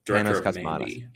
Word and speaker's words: Panos Cosmatos: Panos Cosmatos, yeah.